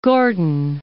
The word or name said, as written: Gordon